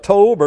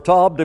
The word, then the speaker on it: Tob: And now he's sent over to the land of Tob or Tob, depending on if that's a long O or a short O.